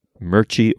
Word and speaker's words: Mirchi: It is M-I-R-C-H-I. Mirchi or Mirchi.